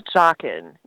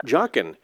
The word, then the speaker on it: Joughin: Charles Joughin, yeah. Joughin.